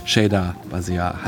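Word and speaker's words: Shida Bazyar: Und noch als kleine Korrektur hinten raus, ich habe es vorhin selber falsch ausgesprochen, Shida Basiar heißt es natürlich.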